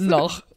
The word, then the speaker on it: noyaux: The creme de noch is spelled N-O-Y-A-U-X. – Oh, noch. Interesting.